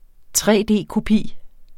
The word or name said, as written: tre-d-kopi